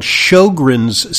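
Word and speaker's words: Sjögren's: She's not aware of this, but there's something called Sjogren's syndrome, which taught me how to pronounce an S-J. S-J in Swedish is pronounced sho, like S-H. So her name is spelled S-J-O-B-L-A-D.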